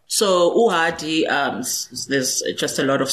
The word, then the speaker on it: uhadi: You play the uhadi and the umrube. – Yes. – Is that how you say it? – Umrube. Uhadi, umrube.